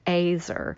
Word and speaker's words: azer: Maybe we should say how it's said, azer. Now. Um, I know that people have different ways of pronouncing it, and it's probably fairly anglicized, but it's um, azer. It's like it rolls the the, the R, um, and uh, I think the the Z is kind of hard.